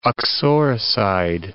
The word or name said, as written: uxoricide